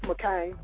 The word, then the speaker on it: McCain: McCant, McCant, is that how you even say his name? Is it McCant? – It's McCain. – McCain.